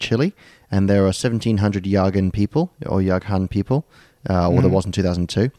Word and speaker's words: Yagan: It's pronounced Yagan. – Yes, let's go with that.